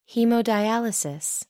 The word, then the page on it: haemodialysis: American English